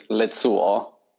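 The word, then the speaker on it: Lezuo: So like the E in my last name is set as an A on the continent. – Okay. – That's why if you think in your head, like my last name is spelled Lima Echo Zulu Uniform Oscar. So it's Lazzuo. But if you think about it as if you say Le, like Les Miserables, like Les.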